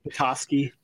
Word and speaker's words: Petoskey: Did I pronounce that right? The Petoskey Stone? – Petoskey.